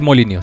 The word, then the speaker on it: Molyneux: Es Molinio, se pronuncia Molinio, según sí, Es Molinio.